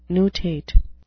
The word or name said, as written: nutate